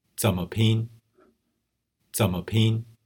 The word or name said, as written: zenme pin